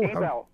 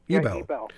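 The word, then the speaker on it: Ebell: So, how do you pronounce the name of is it Ebel or Ebel? – Ebel. – Ebel.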